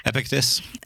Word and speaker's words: Epictetus: Epictetus. – Epictetus. – If you say it really fast. – Probably. Epictetus? – Yeah, why not? – Epictetus. – Epictetus. I was just going to say Epictetus.